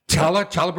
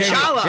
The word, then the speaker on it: challah: I would say challah bread? – Is it challah? – Hey, challah.